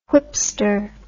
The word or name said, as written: whipster